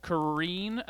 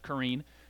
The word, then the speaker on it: Karine: Maybe the person who gets paid to do it, Karine, I think her name, I think you pronounce it Karine, Karine Jean Pierre, the White House spokesman.